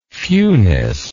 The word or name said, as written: fewness